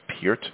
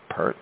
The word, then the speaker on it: Peart: Yes, his name is pronounced Peart, not Pert.